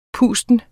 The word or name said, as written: pusten